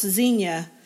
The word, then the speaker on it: Zinnia: Z-I-N-N-I-A. – Yeah. In the middle part of the country, I guess, at least in Oklahoma and in Indiana, it's pronounced Xenia. I do sometimes say Zinnia, but not very often.